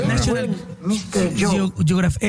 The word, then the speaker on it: National Geographic: se publicó el primer ejemplar de la revista National Geographic. Oh, my goodness. – Ay, lo pronunciaste bien bonito. – National Geographic.